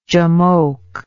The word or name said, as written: jamoke